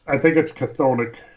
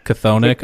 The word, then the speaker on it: chthonic: I don't even know how you pronounce that. That's that weird. – I think it's Chthonic. – Chthonic. Okay.